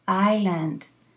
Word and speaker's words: island: Island. – You never pronounce the S, okay? – The story is beautiful.